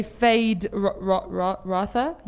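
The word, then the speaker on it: Feyd-Rautha: Also, Austin Butler gained 25 pounds of muscle to play Fade Rotha. Yes, that's how you pronounce him.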